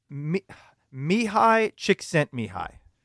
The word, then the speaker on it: Mihai Csikszentmihalyi: It is Mihai Csikszentmihalyi.